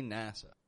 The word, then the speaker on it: NASA: N-A-S-A, for short, we just say NASA. Now note how I make that A sound in there. NASA. NASA. Not NASA. NASA. But NASA. I have heard a lot of people pronounce a clear, tense A sound here. And I'm here to tell you, my friend, we use a wide A, the A. Instead, NASA. NASA.